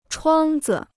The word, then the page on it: 窗子: chuāng zi